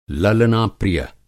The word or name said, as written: ललना-प्रिय